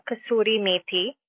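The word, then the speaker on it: kasoori methi: It's called kasuri methi.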